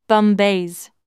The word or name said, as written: bumbaze